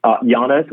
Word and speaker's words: Yanes: And Peter, spell your last name, because it's pronounced Yannis, but spell it. – Yannis, Y-A-N-E-S.